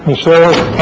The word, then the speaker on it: Musero: My name is Frank Musero, M-U-S-E-R-O.